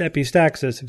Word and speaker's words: epistaxis: So the word's almost like epistaxis, which is pronounced epistaxis, except it's...